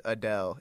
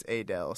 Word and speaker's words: Adele: it's not pronounced Adele, it's Adele. So if somebody came with a long A right away, you knew this person is trustworthy.